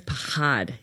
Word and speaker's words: Pachad: It's Pahad.